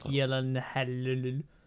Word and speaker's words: Gyllenhaal: Yes, not the film Zodiac, about the Zodiac Killer starring Jake Gyllenhaal. That's how you pronounce his name.